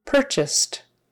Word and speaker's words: purchased: That UR at the front is an ER sound. Purr, purr, like a cat purrs. Purchase, purchased purchased. So the past tense has a T sound on the end, even though it's spelled with an ED. Often those words with ED are pronounced with either a T or a D. Um, and this one is a T because S is unvoiced. So we make the unvoiced T follow it.